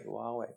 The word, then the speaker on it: Huawei: Huawei, Huawei pareizi.